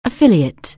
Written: affiliate

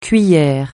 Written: cuillère

